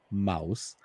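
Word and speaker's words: Maus: Is it Mouse. – Mouse. German for mouse, yeah.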